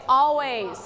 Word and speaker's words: always: everyone say always. – Always. – Okay, if you see S, don't forget to pronounce sound.